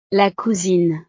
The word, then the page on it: la cousine: frances